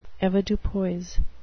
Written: avoirdupois

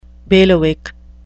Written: bailiwick

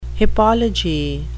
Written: hippology

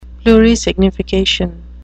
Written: plurisignification

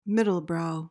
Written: middlebrow

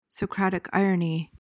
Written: socratic irony

